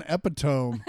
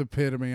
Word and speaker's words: epitome: Whenever I see the word epitome, it's twice before I'm like, what's an epitome? And I'm like, God damn it, that's epitome.